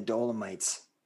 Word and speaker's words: Dolomites: Well, the other thing, just as you were mentioning the Dolomites, did I say that right? That mountain range? – Mm-hmm.